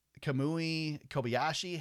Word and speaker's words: Kobayashi: Brody Kostecki finishes 22nd in the 33 car, Mike Rockenfeller 24th, Jenson Button 28th, Kamui Kobayashi.